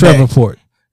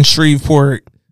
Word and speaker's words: Shreveport: Mispronouncing Shreveport, I get the H and the V's is probably in different places that you're not used to.